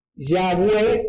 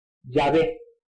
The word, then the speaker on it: Yahweh: Una es Yahweh o Yahweh, que está acá, que es una pronunciación más de los judíos de tipo anglosajones, alemanes e ingleses. Y después tenemos otra pronunciación de judíos más cercano a nuestro idioma, como son los sefardí, judíos españoles, ellos pronuncian Yahvé o Yahvé.